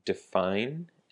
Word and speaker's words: define: Define and definition. So the word define has the emphasis on the second syllable, the fine, and that's a verb that means 给什么什么东西下定义 or 限定.